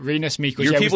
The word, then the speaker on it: Rinus Michels: Is that how we've agreed to pronounce it? – I think that's how you pronounce it. Rinus Michels. – Usually go Rinus Michels, and then I've heard like Renu Michel, and so I don't know what to go with that.